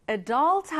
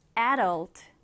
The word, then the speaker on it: adult: 자, 이 단어는 특이하게도 발음하는 방법이 두 가지입니다. 쌤이 방금 발음했던 것처럼 adult 하셔도 되고요. 앞에 강세를 두어서 adult, adult 이렇게 하셔도 됩니다.